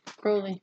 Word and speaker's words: Crowley: Crowley's actually how it's pronounced. – I've always said Aleister Crowley. – Yeah. Well, they say that in last podcast. – Oh. – They bring it up about how it's Crowley, not Crowley.